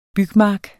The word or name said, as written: bygmark